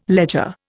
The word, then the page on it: ledger: Indian